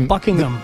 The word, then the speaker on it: Buckingham: Y de hecho, inglés perfecto, el H medio que se, es medio silente. Buckingham.